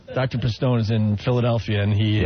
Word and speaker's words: Pistone: The correct pronunciation is Dr. Pistone.